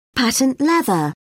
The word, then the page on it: patent leather: British English